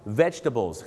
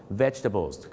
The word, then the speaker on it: vegetables: vegetable vegetable ไม่ใช่ vegetable นะครับ vegetables ครับผม vegetables เขาจะไม่ออกเสียงตัว jet ครับดังนั้นนะครับอย่าลืมครับ vegetables 3 พย